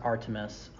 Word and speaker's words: Artemis: And I wonder if, like, the Artemis, I can't say the name because I want to say Artemis, like that Greek, like, god or whatever. – Artemis. – It's pronounced Artemis.